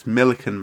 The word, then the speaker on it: Millican: It's Millican, mate. Millican. Like Pelican, but Millican, that's what they said for Red Ed when they said he'd become Prime Minister.